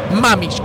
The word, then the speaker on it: Mamich: Mamich, con H intermedia, sí. Muy bien la productora. – Muy bien. – Con H intermedia, la H es muda.